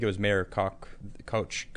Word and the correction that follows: Koch: how do you say his name? I don't know. Whatever. – Koch.